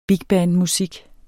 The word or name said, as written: bigbandmusik